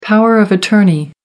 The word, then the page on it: power of attorney: American English